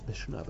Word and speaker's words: Mishnah: It's mishnah, by the way. If you're going to read mishnah, so you can get a bit. If you're going to read with the accent on the end, then you got to read with the proper vocalization.